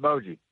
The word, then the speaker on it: Boggia: How do you say that name? – We've always said it Boggy. – Boggy. Okay. All right. That's how Channel 3 was saying it. I'd always said it Boggia, but I don't know.